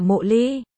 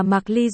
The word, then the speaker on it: Mộ Ly: Nên có khả năng M.U. Ly phải đọc là Mộ Ly. Cơ mà suốt từ đầu chuyện đến giờ toàn để là Mạc Ly rồi nên tôi cố định sửa lại nữa.